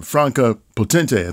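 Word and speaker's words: Franka Potente: Uh, also, I had a little bit of a, a, bit, a bit, of acquaintance with uh, uh, um, uh, Franca Potente, I think is how you pronounce her name. – Correct. Yeah.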